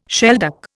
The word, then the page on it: shelduck: Indian